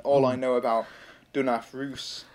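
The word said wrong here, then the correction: Ruse: if I'm pronouncing it correctly, is... – Rusa. – Rusa.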